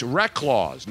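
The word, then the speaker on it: Reklaws: Don's see, but if you just spell it the way it's spelled, R-E-K-L-A-U-S-E, whatever it's spelled, you have to spell it phonetically. Rec, W-R-E-C-K dash laws. That's the way it's pronounced. – Reclaws, like recluse, but Reclaws.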